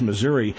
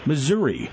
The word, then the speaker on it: Missouri: they pronounced it Missouri. But when they're doing the small towns, it's Missouri. – Yeah, well, that's right. The Harry Truman's home state, let me just call it that, is the only state I know that has two generally recognized pronunciations in standard Midwestern English, depending whether you're in the St. Louis media market or not.